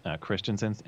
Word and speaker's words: Christensen: Yeah, it's just Matt Christian, Christensen. It's Christian, like the religion, S-E-N.